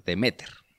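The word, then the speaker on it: Deméter: Perséfone era la hija de Zeus y de Demeter. No, tiene el acento en otro lugar. Demeter.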